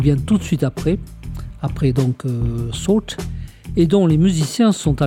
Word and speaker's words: Sault: Salt. Salt, ça s'écrit S-A-U-L-T.